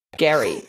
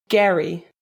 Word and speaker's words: Gerry: I say this wrong every time, even though I've watched the show. Gary Turner. Gary. It's spelled G-E-R-R-Y, and my brain just like kerplunks each time I read it. – Wait, spell his name again? – G-E-R-R-Y. Gary. – What? – Yeah. It's Scottish, apparently.